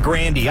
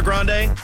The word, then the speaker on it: Grande: you know we've been saying her last name wrong this whole time? You actually pronounce it Grandy.